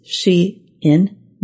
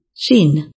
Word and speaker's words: Shein: Online fast fashion business Shein is Shein, not Sheen.